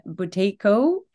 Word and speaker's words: Buteyko: He's also director of education and training at the Buteyko Clinic.